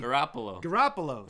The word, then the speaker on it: Garoppolo: Hey, how about Jimmy how do you say his last name? – Gargantuan. – Jimmy G. How about Jimmy G? – Garoppolo. There you go.